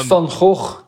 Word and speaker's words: Van Gogh: That sounds like a swear word. – It sounds like clearing your throat, yeah, but it's... – Holy shit, that's hilarious. – Yeah. Van Gogh, that's the real way to pronounce it.